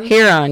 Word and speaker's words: Huron: How do you say that Huron? – One? Huron, yeah. Michigan, Ontario, and Erie.